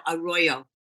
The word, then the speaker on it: Arroyo: So the native people who lived here long before we did, they had sacred places like the Devil's Gate, where the, I believe it's pronounced Arroyo, A-R-R-O-Y-O. – Wait. No, Arroyo. It's Arroyo. Arroyo.